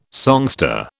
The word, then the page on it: songster: British English